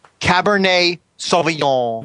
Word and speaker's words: Cabernet Sauvignon: He's right about the French pronunciation, but it's Cabernet. So you're going to drop the E-T. – Oh. Now, try saying that, please. – Cabernet Sauvignon.